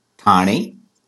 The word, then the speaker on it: Thane: So currently I'm in this beautiful city called Thane. That's the Marathi pronunciation, but it's a neighboring city of the city of Bombay, or Mumbai, as it is called now.